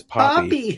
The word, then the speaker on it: Poppy: Poppy, like P-A-P-I? You're going to go Latin? – No, my actual nickname is Poppy. P-O-P-P-Y.